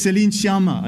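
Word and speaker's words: Céline Sciamma: It's Celine Siamar.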